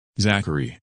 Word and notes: Zachary: English

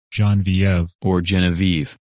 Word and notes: Genevieve: English

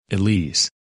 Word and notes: Elise: English

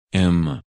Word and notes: Emma: English

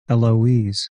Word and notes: Eloise: English

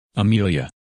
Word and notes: Amelia: English

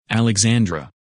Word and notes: Alexandra: English

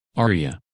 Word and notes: Aria: English

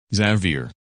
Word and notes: Xavier: English

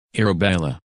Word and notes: Arabella: English

